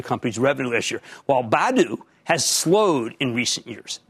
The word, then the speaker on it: Badu: Now, this would be an easier call, frankly, if it were just Bumble without the Badu, which, by the way, is pronounced like Scooby-Doo, Badu baggage.